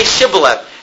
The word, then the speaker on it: Shibboleth: Okay, say Shibboleth. And they're like, they couldn't make that sh sound.